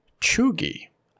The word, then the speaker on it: cheugy: Today's word, chuggy, an adjective meaning the opposite of trendy, out of date, or uncool.